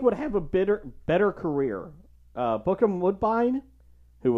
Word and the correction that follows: Bokeem Woodbine: Bokeem Woodbine, obviously. – I totally butchered that name.